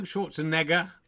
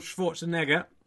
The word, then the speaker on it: Schwarzenegger: Schwarzenegger. Thank you. You're going to get into trouble. It was a mispronunciation. I apologise. Arnold Schwarzenegger.